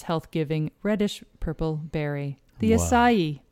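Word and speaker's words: açaí: A-C-A-I. – Acai? Is that what you're It's acai. – Acai, oh. – And I saw so many different pronunciations of this. So some people will say acai, acai, or just acai, but you actually stress the last syllable of E. So it's acai.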